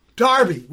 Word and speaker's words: Derby: Derby, which is spelled Derby. I've That's learned this. – I've learned this, and also a great lady on electronics.